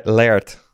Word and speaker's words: Laird: Hoe spreek je dat uit? Dat weet jij altijd. – Laird.